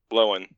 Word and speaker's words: Lohan: It's not Lindsay Lohan. Apparently, it's Lindsay Lohan. – Lohan.